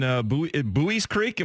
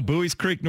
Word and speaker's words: Buies Creek: I started this program, Keith Hills Golf Club in Buies Creek. Am I saying that right? – You are one of the few. Yes, sir. – There you go. Buies Creek, North Carolina.